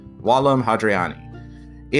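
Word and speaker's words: Vallum Hadriani: You'll often see it spelled as Vallum, but in classical Latin, the V is pronounced as a W, so Wallum Hadriani.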